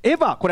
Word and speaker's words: AVA: まず最初の候補はこちら、エヴァ、これ AVA と書いてエヴァです。